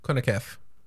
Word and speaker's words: CONCACAF: CONCACAF.